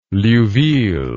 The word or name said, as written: liouville